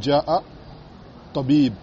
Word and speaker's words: جاء الطبيب: Si il y a un autre mot qui vient derrière, le hamza, il tombe. Et on prononce directement. Ja, tu dis ja'a tabib.